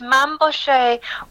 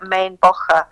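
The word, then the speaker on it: Mainbocher: Well, no, it's Mabouchet when he's being a French couturier, but then it's actually, like, I think he's of German heritage, so I think it's like Main Bocher if you're saying his name. – Oh, yeah, as it would have originally been.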